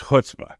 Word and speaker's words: chutzpah: Bravery that borders on rudeness, Lauren, is chutzpah, which, by the way, rhymes with foot spa, if you're trying to figure out how to say that properly at home.